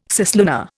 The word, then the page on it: cislunar: Indian